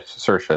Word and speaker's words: Saoirse: Saoirse Ronan? – Oh, yeah, Saoirse Ronan.